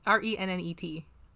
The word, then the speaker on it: rennet: So Renette is or Renee Renee How do you spell it? – R E N N E T. I think it's rennet. Rennet.